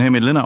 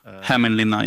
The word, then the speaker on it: Hämeenlinna: Du bist in Hämeenlinna aufgewachsen. Ist das die richtige Aussprache? – Ja, Hämeenlinna, ja.